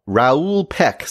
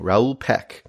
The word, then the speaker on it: Raoul Peck: And that is I Am Not Your Negro by Raoul Peck. Sorry, I got the name wrong. Raoul Peck.